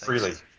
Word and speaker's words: Frehley: Frehley.